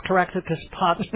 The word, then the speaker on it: Caractacus Potts: I can't believe you pronounced Caractacus Potts perfectly.